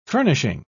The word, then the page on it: furnishing: British English